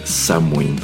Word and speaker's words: Samhain: es precisamente Samhain. Así se pronuncia porque esta es una de dos palabras o frases de donde nos viene la más contemporánea Halloween.